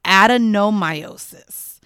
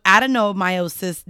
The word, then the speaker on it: adenomyosis: I did want to correct as well our pronunciation of what Kenzie was diagnosed with. It is actually adenomyosis. So adenomyosis does occur when the tissue that normally lines the uterus grows into the muscular wall of the uterus.